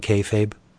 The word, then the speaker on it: kayfabe: That's how you say it, breaking kayfabe.